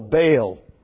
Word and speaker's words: Baal: Baal you call Baal, but it is Baal in the Hebrew, and we will retain the correct pronunciation.